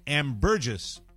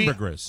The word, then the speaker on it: ambergris: I would think it would ambergris. Be ambergris, because gris is gray in French. – But yeah, it's Australia, so they probably pronounce the gris.